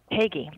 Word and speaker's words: Hagey: Your last name pronounced Hagey? – Hagey, that's right.